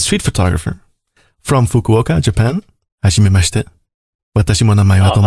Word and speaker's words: hajimemashite: Hajimemashite.